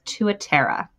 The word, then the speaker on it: Tuatara: is Tuatera. – Tuatera. Okay. – Tuatera. – Now spell that for our listeners. – Yes. T-U-A-T-A-R-A.